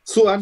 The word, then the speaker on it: Sochan: Alors pour l'instant, on ne vous a pas forcément dit vers qui nos cœurs euh, allaient pencher, mais il y a, comment on prononce alors, je ne sais pas, Jérémy Sohan, Sochan Sohan, je prononce Sohan.